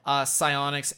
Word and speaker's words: Psyonix: They bought Psyonix. I'm pretty sure that's how you say it. – Yeah. – Psyonix, and they're the studio behind Rocket League, which is an incredibly popular game.